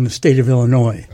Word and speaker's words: Illinois: And Illinois, Illinois's, how do you say that? Illinois's? – Illinois. – No, but possessive. Oh.